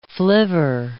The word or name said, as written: flivver